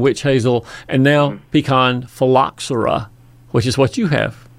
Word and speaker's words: phylloxera: And let me say that word one more time so you'll know how to pronounce it for people that ask about it. It's called phylloxera.